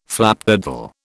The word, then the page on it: flapdoodle: British English